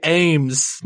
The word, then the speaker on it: Ames: I'm sorry, how did you pronounce his last name? I thought it was Ames. – Amez. A-A-M-E-S. What? Oh, God. I gotta forget. It's Westaboo. – Westaboo. – I'm reading it as if like, oh, break it up into multiple syllables. Amez. But it's fucking Westaboo, so I have to say Ames.